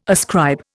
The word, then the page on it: ascribe: Indian